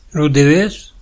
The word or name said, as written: Deves